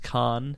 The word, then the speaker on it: Kahan: His last name is Kahan, and it's pronounced Kahn. I don't know why, but that's just the way it is.